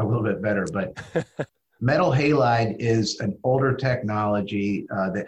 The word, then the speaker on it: metal halide: You mentioned the halide bulb. Am I saying that right? – Yeah, metal halide.